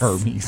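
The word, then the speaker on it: Hermès: What about, let's go to another luxury brand, H-E-R-M-E-S. Hermes? – Hermes. – Hermes. – Hermes? – Hermes. – Hermes. I can't afford them. – It's Hermes. A-I-R. – Hermes. – That's how you should pronounce it.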